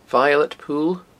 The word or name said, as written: violet pool